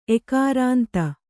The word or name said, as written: ಎಕಾರಾಂತ